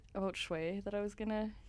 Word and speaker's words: Schwey: S-C-H-W-E-Y. Shui.